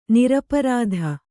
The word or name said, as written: ನಿರಾಪರಾಧಿ